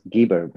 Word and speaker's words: Gibor: Funny you should say that, because my surname, which is Gibor, but I live in Israel, and in my passport and my ID document, it's pronounced Gibor.